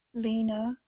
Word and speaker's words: Lena: Lena Muramoto. – Spell that, please. – Lena is spelled L-E-N-A. Muramoto, okay. – M-U-R-A-M-O-T-O.